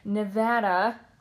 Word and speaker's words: Nevada: And it's so good because she came from Nevada. I said it right, right? – Good job. You did.